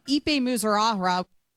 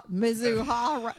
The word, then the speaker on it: Mizuhara: Ipe Muzorahra.